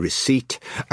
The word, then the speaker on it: receipt: That's why we have a silent P in receipt.